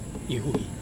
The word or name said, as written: huhi